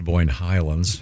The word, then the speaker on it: Boyne Highlands: Is this some huge plaque at the country club in Boyne Highland? Is that where your ring is? – Where is it? – First of all, it's pronounced Boyne Highlands.